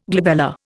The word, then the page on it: glabella: Indian